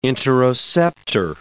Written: interoceptor

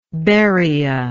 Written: beria

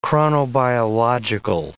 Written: chronobiological

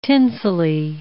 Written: tinselly

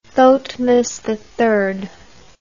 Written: thotmes III

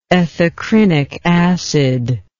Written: ethacrynic acid

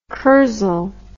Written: kursaal

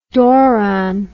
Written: doron

